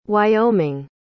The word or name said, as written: wyoming